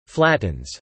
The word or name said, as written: flattens